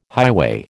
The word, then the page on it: highway: American English